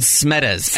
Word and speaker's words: Smedes: How do you say his last name? – Smedes. S-M-E-D-E-S.